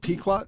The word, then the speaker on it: Pequot: So anyway, you were there with the Pequot. – So we went. To, we is it Pequot. How do you spell that? – P E Q O T.